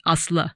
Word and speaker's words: Asle: So it's a story of two men who are pretty confusingly both named Asla. It's spelled A-S-L-E, the name.